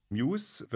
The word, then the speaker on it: MUSE: MUSE wird es ausgesprochen, aber geschrieben als MUSE.